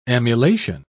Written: emulation

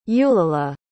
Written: eulalie

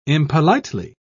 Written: impolitely

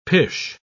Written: pish

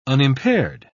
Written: unimpaired

